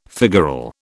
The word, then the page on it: figural: British English